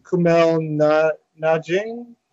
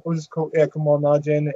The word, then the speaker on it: Kumail Nanjiani: Kumail Nanjiani. – Yes. – Yeah, Kumail, I think it's Kumail. Kumail Nanjiani, and then we got Mondan Sok, who's the forgotten one.